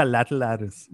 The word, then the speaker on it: Lateralus: How do you pronounce it? – Lateralus. Now you got me tongue-tied. But yeah, Lateralus. That's how I would say it.